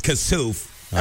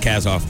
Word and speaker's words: Kassouf: I guess it's Kassouf. I thought it was Kasoff.